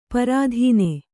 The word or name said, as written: ಪ್ರತನ